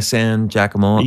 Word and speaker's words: San Giacomo: And so they were producing, Brillston Gray, a sitcom for Laura San Giannomi, is that how you say it? – Yeah, San Giacomo.